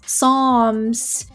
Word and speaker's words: Psalms: But I guess Psalms in English, they're spelled with a P. You know, the Psalms from the Bible. But of course, the P is silent. Anyway, in Spanish, Psalms are spelled with an S.